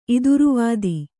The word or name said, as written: ಇದುರುವಾದಿ